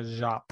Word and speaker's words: Jaap: But um, I think it's a soft J. It's a Jop.